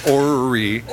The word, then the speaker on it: orrery: Orrery.